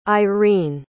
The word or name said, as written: irine